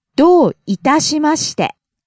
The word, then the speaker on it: どういたしまして: ど starts high, falls on the second syllable. – どう、どう。 – いたしまして starts low, rises on the second syllable た、falls on the second to last syllable.